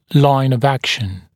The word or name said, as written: line of action